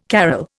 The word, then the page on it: carol: Indian